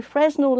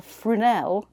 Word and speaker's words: Fresnel: So a Fresnel lantern, pronounced Fresnel or Frunel,